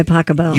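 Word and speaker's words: Pachelbel: Canon D by Pasquale. – By Pacabell.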